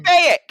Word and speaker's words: Baek: Beak. I use Beak. – His name is not fucking Beak. His name is B-A-E-K. That's it, Beak.